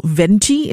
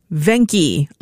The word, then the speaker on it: Venchi: Now, Hazeline did say that her hubby, who I think is Italian maybe, says that what we call Venchi in Singapore, V-E-N-C-H-I, is actually pronounced Venki.